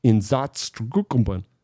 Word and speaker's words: Einsatzgruppen: Well, during the invasion of Russia, mass executions by exhaust gas were performed by the Einsatzgruppen.